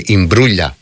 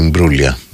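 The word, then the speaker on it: Imbruglia: Νομίζω ότι προφέρεται Νάταλη Ιμπρούλια κανονικά. Αλλά τουλάχιστον στην Ελλάδα, επειδή αυτό το είδος το προφοράς το έχουμε συνδέσει τέλος πάντων ότι και καλά το κοροϊδεύουμε, ίσως να το κάναμε μόνο εμείς Νάταλη Μπρούλια.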